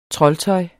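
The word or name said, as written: TROLDTØJ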